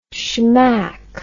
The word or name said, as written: schmack